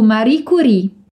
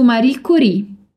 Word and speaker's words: Marie Curie: Embora o nome seja francês, a gente pronuncia alla italiana. Então, vamos repetir. Il nuovo film su Marie Curie. Il nuovo film su Marie Curie.